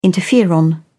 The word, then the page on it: interferon: British English